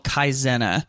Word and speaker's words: Kaizena: And the first one I wanted to touch on is this thing called Kaizena. It's spelled K-A-I-Z-E-N-A.